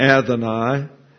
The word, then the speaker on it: Adonai: They prefer, as we'll note here, the word Adonai, or Adonai is the proper pronunciation.